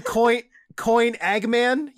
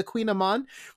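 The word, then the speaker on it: kouign-amann: How do you pronounce it? It's like, oh, the Queen Amon. Well, why didn't you spell it Q-U-E-E-N in the first place then?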